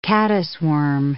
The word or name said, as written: caddisworm